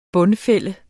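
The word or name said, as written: BUNDFÆLDEDES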